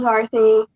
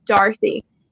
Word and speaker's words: Dory: And my nickname when I was younger was actually Dory, D-O-R-Y. But when I was in 7th grade, I decided I wanted to be called Darthy. And so the actual spelling, or the spelling is with O's, but the pronunciation is an English pronunciation and Australian pronunciation with the A, more the Darthy.